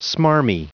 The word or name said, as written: smarmy